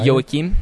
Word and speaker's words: Joachim: Joachim. – Joachim? – Yes, Joachim Trier.